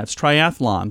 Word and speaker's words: triathlon: Yeah, it's triathlon. There's no extra A. It's not triathlon. It's triathlon.